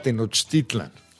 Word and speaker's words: Tenochtitlan: A ver, la primera duda, porque la primaria me enseñaron que era Tenochtitlan. Pero aquí veo que tiene una, en su bellísima portada, que está increíble el libro, tiene acento en la A. – Fíjate que ya está aceptado de una u otra forma. Igual que Teotihuacán o Teotihuacán, es el mismo caso, ya se acepta sin problema.